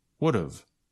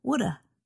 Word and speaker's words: would've: But native speakers usually shorten these with contractions, so it sounds like this. Should've, could've, would've. – And sometimes native speakers reduce or shorten the pronunciation even more. So it sounds like this. Shoulda, coulda, woulda.